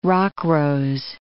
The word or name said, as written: rockrose